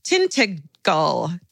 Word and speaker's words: Tintagel: The same day we visited a little further along the coast of, okay, but thankfully they told me the pronunciation, Tintagel Castle.